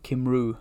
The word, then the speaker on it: Cymru: Is it Cymru? – Cymru.